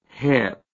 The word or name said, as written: Cheb